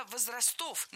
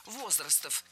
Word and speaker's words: возрастов: Было сказано «возрастов», но это неверно. Ударение в нашем слове должно падать на первый слог «возрастов».